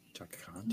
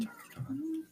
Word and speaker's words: Chaka Khan: Chaka Khan, Chaka Khan.